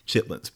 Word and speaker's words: chitlins: You can write it that way, but it's actually spelled chitterlings. I pity anybody who calls them that. I'll have some chitterlings in a cup, please. No, you have to say chitlins, but it's spelled chitterlings.